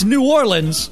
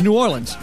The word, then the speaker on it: New Orleans: It right. It's New Orleans... But you say it quickly, so it's New Orleans.